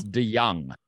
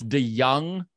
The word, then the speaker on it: DeJong: It's DeJong. So it's DeJong, like a Y instead of a J. DeJong.